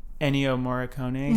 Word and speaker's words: Ennio Morricone: But it sounds like, I'm going to say this soundtrack producer dude, Ennio Morricone. – Mm-hmm. Yeah. You said it correctly.